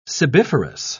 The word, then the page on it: sebiferous: British English